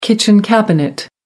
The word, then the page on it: kitchen cabinet: American English